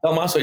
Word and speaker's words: Dalmaso: I'm saying that correctly, right? – Dalmaso, yeah. It's funny. Most people butcher it, but you nailed it.